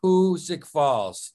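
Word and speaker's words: Hoosick Falls: Don't ever say it wrong again. It's Hootsuck Falls.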